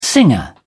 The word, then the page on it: singer: British English